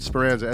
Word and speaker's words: Speranza: Find me on Instagram at Damian, D-A-M-I-E-N. That's comedy. And on Facebook, it's Damian Speranza, S-P-E-R-A-N-Z-A.